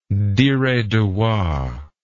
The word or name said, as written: diredawa